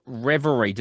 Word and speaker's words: Reverie: The new album is called Reverie. Did I pronounce that right? – Absolutely.